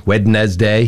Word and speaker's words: Wednesday: R-E-C-O-M-M-E-N-D. – Yeah. Mm. Recommend. It's not. It's recommend. – People have trouble with the word Wednesday. Wednesday. – Yeah. Wednesday. – Yep. I agree with that one. – Because the D is silent. – Mm-hmm. Wednesday.